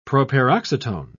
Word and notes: proparoxytone: British English

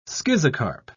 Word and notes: schizocarp: British English

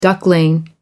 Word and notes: duckling: American English

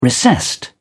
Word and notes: recessed: British English

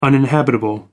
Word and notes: uninhabitable: American English

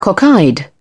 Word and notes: cockeyed: British English

